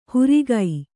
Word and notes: ಹುರಿಗೈ: hurigai

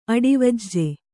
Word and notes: ಅಡಾವುಜ: aḍavuja

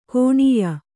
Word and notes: ಕುಣಿಯ: kuṇiya; dial.

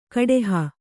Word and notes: ಕಡೆಹ: kaḍeha

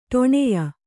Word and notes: ಠಾಣ್ಯ: ṭhāṇya

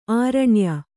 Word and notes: ಆರುಣ್ಯ: Kannada

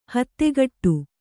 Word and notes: ಹತ್ತಗಡೆ: Kannada

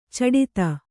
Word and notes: ಛಡತ: chaḍata

